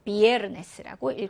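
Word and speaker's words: viernes: 두 번째 단어는 끝에서 두 번째 음절 비에르네스라고 읽어주시면 돼요.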